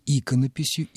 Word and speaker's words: иконопись: Что же касается ударения в слове «иконопись», то это традиция. Вот такова традиция, монастырская традиция. Так говорили, наверное, и в XIV веке. И мы с вами ведь прекрасно знаем, что монастырь — это государство в государстве, это свой мир, это царство единомышленников, людей, которые свято хранят свои традиции. Поэтому вот эти неудобные ударения — тем не менее, вот в этой среде сохраняются. И поэтому иконопись до сих пор остается иконописью и не превращается в иконопись.